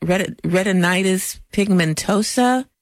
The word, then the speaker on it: retinitis pigmentosa: Is that retinitis pigmentosa? Did I pronounce that correctly? – You got it right. Yes, you did.